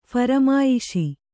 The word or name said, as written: ಫರಮಾಯಿಶಿ